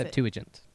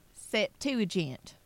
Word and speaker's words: Septuagint: I always feel like I'm saying that wrong. – You are. – Which is, I am. How is it? Septuagint. – Septuagint. Okay, got it.